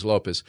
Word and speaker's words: Lopes: And to clarify, Lopez is spelled with an S. – S, so not Z. So Z is Spanish. – You're from Brazil, Portuguese. – Lopes. Lopes. This is how they pronounce it. The Phillies had a base coach named Davey Lopes. – Lopes. – L-O-P-E-S.